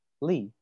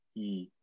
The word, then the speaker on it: Lee: So just like in China, Lee. – Oh, yeah, yeah. Yeah, Lee, right? But we don't pronounce it Lee. We say E without the L.